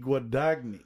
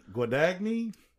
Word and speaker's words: Guadagni: It's Nikki Guadagni? – Guadagni. Yeah, Guadagni.